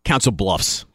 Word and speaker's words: Council Bluffs: Is it pronounced Council Bluffs or Bluff? – Council Bluffs.